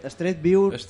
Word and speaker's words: Street View: Street View. – No, és que jo pronuncio en dues S, eh?